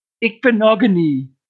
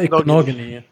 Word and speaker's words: Igbinoghene: Ich hatte die einzelnen Silben bei der Sonderfolge falsch ausgesprochen. Rico hat mich berichtigt. Das heißt, Igbinoghene. – Ja, Noah Igbinoghene.